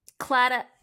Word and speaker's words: Claddagh: Claddagh.